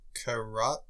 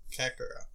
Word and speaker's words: Kakarot: Dragon Ball Action RPG Karate? I think that's how you pronounce that. – Kakarot.